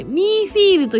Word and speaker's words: Menfeel: ミーフィールと読むのが SSG 流なので、綴りはメインフィールですが、 M-E-N-F-E-E-L と書いてミーフィールと呼びます。